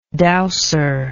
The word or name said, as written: douser